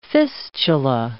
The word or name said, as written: fistula